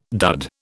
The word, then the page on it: dud: British English